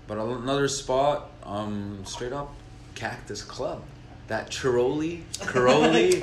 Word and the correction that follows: Creole: Okay, first off, this guy doesn't know how to say it. It's Creole chicken.